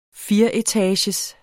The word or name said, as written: fireetagers